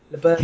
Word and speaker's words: LaBerge: LaBerge.